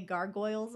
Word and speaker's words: gargoyle: And now I just get to hear you say Gargoyles a lot and really hear the goy. – Gargoyles. Now I'm just really, really sensitive about that. Now that you've pointed it out, Gargoyles. That's how you pronounce it. This is the word, gargoyle. All right.